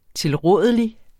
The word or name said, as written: tilrådelig